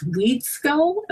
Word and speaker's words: Wedeskyull: It's like you go in this whole different direction, yet the uh, thread of I think it's Wedeskyull. Am I cr- pronouncing that correctly? – Yeah, you're one of the few people who did.